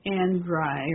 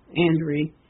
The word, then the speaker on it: Andriy: And is spelled A-N-D-R-I-Y, Andry, or Andry, Melnik, M-E-L-N-Y-K.